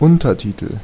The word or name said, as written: Untertitel